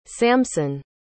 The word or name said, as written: samson